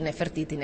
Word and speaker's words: Nefertiti: O sigui, una cosa és la dona de la reialesa, que tenim alguns noms com aquests que els has pronunciat perfectament, Hatshepsut, Nefertiti, Nefertari, etc, etc.